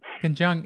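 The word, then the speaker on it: Ken Jeong: Is that how you say his name? Ken Chung? – Ken Chung. – Yes. He was our, I mean, we gave him some of his first acting jobs.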